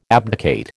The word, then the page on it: abdicate: American English